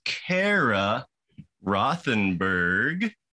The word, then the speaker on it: Cara Rothenberg: And with me today is Cara Rothenberg.